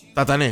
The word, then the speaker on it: Tatané: Tatané, exactamente. Tatané, con acento en Tatané. La, con tilde en la E final.